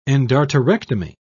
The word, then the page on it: endarterectomy: British English